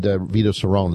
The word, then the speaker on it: Cerrone: And Vito Cerrone is the way it's pronounced, by the way, just in case you're wondering. That E is silent on the end.